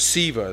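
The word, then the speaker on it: Sceva: These seven sons of Siva is actually how it's pronounced.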